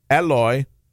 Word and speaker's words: Eloy: Eloy. Don't pronounce the long E. Eloy Jimenez.